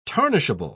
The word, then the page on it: tarnishable: British English